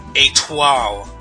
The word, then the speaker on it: étoile: Just wanted to point out that the dancer relic is pronounced étoile, not étoile.